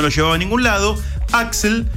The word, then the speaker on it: Axl: Porque es AXL. – Exactamente. – Pero se pronuncia como Axl.